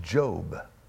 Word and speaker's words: Job: It's the word Eov. Eov is the name of a person in the scriptures. His name, how we would pronounce it, is Job. Job.